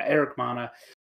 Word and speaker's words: Eric Bana: Is it Eric Bana? Yeah. I want to make sure I got his name right. Yeah, Eric Bana, who's fantastic in this movie.